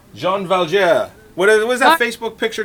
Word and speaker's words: Jean Valjean: It's Jean Valjean.